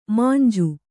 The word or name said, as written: ಮಂಜು